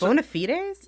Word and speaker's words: bona fides: Wait, bona fides? – Yeah. – Is that how you say it? – Well, yeah. – Well, it's a way to say it? – That's the old-timey way to say it.